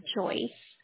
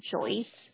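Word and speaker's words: Joice: My name is Joyce. – How do you spell that? – It's a J, O, I, C, E, Joyce. – Okay. K-O-I-C-E? – J is in Juliet.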